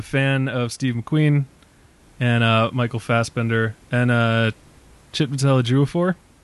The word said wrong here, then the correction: Chiwetel: Did I get that right? Did I nail that? – I say Chiwetel Ejiofor.